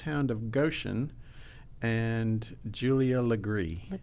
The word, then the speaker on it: Legare: We were talking about the, you know, common mispronunciations of South Carolina names, and Julia's last name is spelled L-E-G-A-R-E, but in South Carolina, that's pronounced Legree. – Legree.